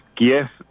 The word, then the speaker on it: Kiev: Kiev, digamos, sería la pronunciación en ucraniano. Kiev es la pronunciación en ruso a la que estábamos acostumbrados.